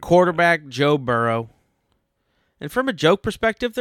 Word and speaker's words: Burrow: You could say that he is like a little hedgehog and he likes to burrow into the ground. – Mm-hmm. – That's a silly one. Second, Burrow rhymes with furrow.